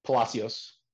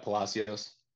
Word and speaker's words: Palacios: Palacios. – I would have not said it that way. Palacios. – Yep, Palacios.